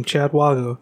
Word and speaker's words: Chad Wago: In case you're ever trying to remember how to pronounce my name, Chad Wago, just remember it rhymes with sad doggo.